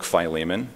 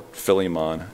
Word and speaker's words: Philemon: It was during this time of imprisonment where he wrote the book Philemon, or Philemon, as I realized I was pronouncing it wrong the whole time.